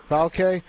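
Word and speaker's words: Valcke: This is according to FIFA Secretary General Jerome, is it pronounced Valkyrie, I believe? – Yeah. – Valkyrie.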